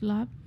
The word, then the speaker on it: larb: It's pronounced lap. It is spelled L-A-R-B. So if you're at a Thai restaurant, you're ordering larb.